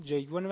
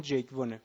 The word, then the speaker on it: جیگ وون: حالا دوستان منم زحمت کشیدن این طرف اون طرف در مورد اینکه اسم تلفظ این اسم چیه زحمت زیاد کشیدن و به این نتیجه رسیدیم که اسم جیگ وون، و الانم فکر میکنم که همون درست باشه، جیگ وون نیست، تلفظ کره‌ایش همون جیگ وونه.